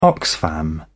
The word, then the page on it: Oxfam: British English